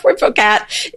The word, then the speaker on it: Puiforcat: And the Poifocat is a Puiforcat.